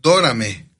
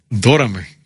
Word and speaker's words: Dorame: Dorame. – Dorame, sí, sí. Híjole. Con acento en la O.